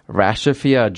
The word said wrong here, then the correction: Rashida: Jones? – Rashida Jones.